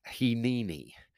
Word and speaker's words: hineni: It's pronounced hineni. Anybody want to say that out loud wherever you are and wherever you're listening? Hineni.